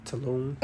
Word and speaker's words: Tulum: Tulum. – Yeah. – Tulum, how you say it? – Yeah.